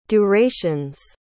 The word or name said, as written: durations